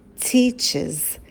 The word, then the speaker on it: teaches: Первое, когда у нас глагол заканчивается на шипящие, кс, ш, ш, с, то в третьем лице прибавляется окончание es, и оно произносится через звук i. Is. Например, глагол teach заканчивается на шипящий ch, и она преподает будет she teaches. Teaches, да, звук и появляется, чтобы вам было удобнее, он разбавляет эти согласные, чтобы их не было так много на стыке.